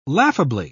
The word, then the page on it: laughably: British English